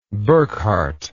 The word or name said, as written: burckhardt